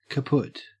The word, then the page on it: kaput: Australian English